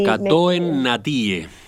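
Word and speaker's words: Katoen Natie: Porque Catunasi, no, parece que es la forma de Catunasi, pronunciarlo. Sí, Catoen Natie.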